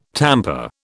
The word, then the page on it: tamper: British English